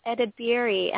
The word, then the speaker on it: Edebiri: So, Edwin Edebiri, am I saying your name right? – That is very right.